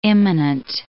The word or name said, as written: imminent